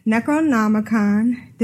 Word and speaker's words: Necronomicon: I'm here with Necronomicon. Did I say that right? – Yes, you did. That was perfect.